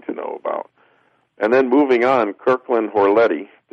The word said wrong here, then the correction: Horletti: The H is silent. It's Warletti.